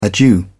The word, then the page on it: adieu: British English